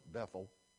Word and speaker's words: Bethel: So Jacob takes his people to Bethel. I know we pronounce it Bethel. They pronounce it Bethel.